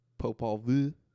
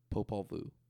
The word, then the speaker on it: Popol Vuh: Popol Vuh. Popol Vuh. I have to say it like French. Okay.